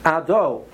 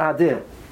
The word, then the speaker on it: Ado: So a person should be aware that you know that the correct way to say Hashem's name is Ado, not Adi.